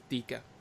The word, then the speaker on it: Ditka: Uh, I, I, I wanted to pronounce it Ditka, or is it Ditka? – Yeah, well, cop, uh, for copyright purposes, it's Ditka.